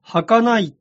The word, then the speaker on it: 儚い: 松田聖子さんが主演した映画、野菊の墓の主題歌は、人の夢とペンで書けば、儚いって読むのですね。